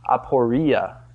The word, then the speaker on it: aporia: The word that he used for perplexed is the word aporia in Greek. Is that right, Rob? Yes, I pronounced it right.